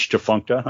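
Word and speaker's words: Tchefuncta: And I guess I'm saying it right because you spell it T-C-H-E-F-U-N-C-T-A. I mean, that's Chifuncta, huh? – Don't fake the Chifunct on the nasty dunk.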